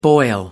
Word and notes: boil: American English

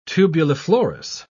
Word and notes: tubuliflorous: British English

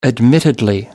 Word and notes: admittedly: American English

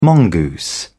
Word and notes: mongoose: British English

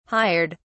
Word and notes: hired: British English

